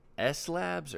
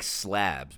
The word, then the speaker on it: S Labs: I don't know if it's S S Labs or S, yeah. It's spelled like slabs, but it's S Labs for spatial labs.